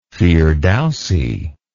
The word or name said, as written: firdausi